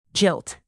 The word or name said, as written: jilt